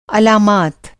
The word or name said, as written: अलामत